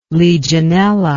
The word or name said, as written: legionella